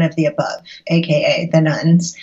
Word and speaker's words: nones: Well, I know in reading your book, you talk about the nuns. So what are you referring to there? And why are their numbers rising so dramatically? – Sure. So the nuns, and that's spelled N-O-N-E-S, not nuns like Catholic nuns.